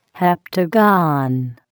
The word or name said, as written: heptagon